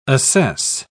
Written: assess

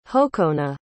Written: hokona

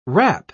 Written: wrap

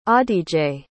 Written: adige

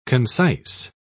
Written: concise

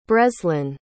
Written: breslin

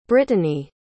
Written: britany

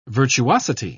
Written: virtuosity

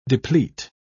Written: deplete

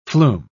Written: flume

